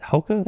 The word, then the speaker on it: Hoka: How's it, Hoka? That's how it's pronounced? – Yeah, I own them.